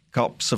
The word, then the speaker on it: Kaapse: Kaapse Vonkel, yeah.